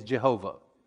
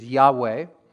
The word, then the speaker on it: Yahweh: Or we would pronounce it the way we hear it often today is Jehovah. We've added vowels and done all sorts of things to it in the English to make it, make it sound um, pronounceable, make it pronounceable for us. But really, I think the way they would say it is Yahweh, and um, means Lord Jehovah.